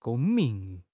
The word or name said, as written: 公民